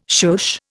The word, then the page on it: shush: Indian